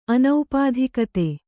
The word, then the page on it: ಅನೌಪಾಧಿಕತೆ: Kannada